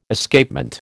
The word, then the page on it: Escapement: American English